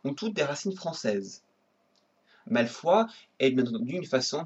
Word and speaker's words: Malfoy: Le nom Malfoy, prononcé Malfoy en anglais, vient du français.